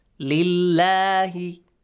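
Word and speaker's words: Lillahi (لله): Lillahi. Not lillahi. Because li before the word Allah. Li Allahi it is. Li Allahi. Lillahi.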